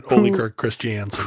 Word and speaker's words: Ole Kirk Christiansen: The story begins with Ole Kirk Christiansen. I'm probably saying that wrong. – No, that's right. Ole Kirk Christiansen.